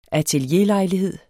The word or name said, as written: atelierlejlighed